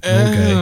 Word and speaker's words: Norgay: His name's Tenzing Norgay, and I always think that when I see Lars from Norway, I'm like, what rhymes with Norway? – Norgay.